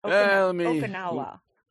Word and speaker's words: Okinawa: Okinawa.